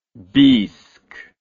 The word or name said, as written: biisk